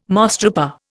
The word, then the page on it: mosstrooper: Indian